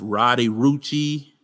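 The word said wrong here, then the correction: Roddy Ricch: Let me get his name right. I don't want to butcher his name. I heard about this guy. Yeah, his name is Roddy Rich.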